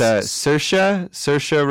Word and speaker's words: Saoirse: I think it's Saoirse Ronan. – Saoirse, is it? – I think that's how you pronounce it. Yeah, it's an Irish name.